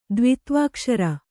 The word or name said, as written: ದ್ವಿತ್ವಾಕ್ಷರ